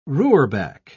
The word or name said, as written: roorback